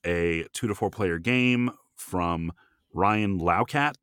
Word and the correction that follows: Laukat: Lockett. Lockett. – Well, it looks like Laukat. Okay. ah, either way, either way. – Lockett is spelled like Lockett. This looks like Laukat.